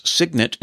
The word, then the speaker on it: Cygnett: Signet is spelled C-Y-G-N-E-T-T.